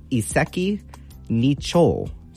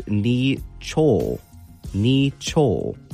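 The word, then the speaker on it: iseki nicho (一石二鳥): So again, it's going to be iseki nicho. So it's going to be pronounced in two different words here. So the first one is iseki, iseki, and the second part is nicho, nicho.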